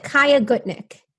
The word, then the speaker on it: Chaya Gutnick: So I pronounce it Chaya Gutnick.